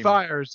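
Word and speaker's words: Fiers: Is it Spires? I don't know how to pronounce his last name. – Fires, yeah.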